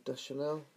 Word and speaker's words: Deschanel: Deschanel. – Deschanel.